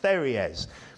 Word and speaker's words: pteruges: And I think I've pronounced that right, but it's spelt, if anyone's wondering, P-T-E-R-U-G-E-S, but I think it's pronounced pteres, which was basically this sort of feathered skirt style belt protecting the loins.